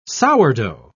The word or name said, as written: sourdough